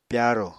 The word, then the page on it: pyarò: Apatani